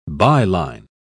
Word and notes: by-line: British English

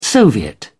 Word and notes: soviet: British English

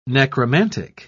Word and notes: necromantic: British English

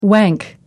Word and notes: wank: American English

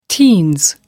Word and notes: teens: American English

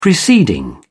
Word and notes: preceding: British English

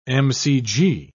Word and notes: mcg: British English

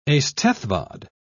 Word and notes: eisteddfod: British English

